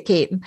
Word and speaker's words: Keating: or Caton, as we say in Tipperary. It's spelled Keating, but we say Caton.